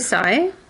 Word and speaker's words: Tsai: Is it Shy? How do you say his last name? – I would say Tsai.